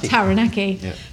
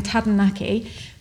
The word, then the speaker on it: Taranaki: They said, if you have a problem rolling your R's, as in Taranaki, replace the R in Taranaki for a D.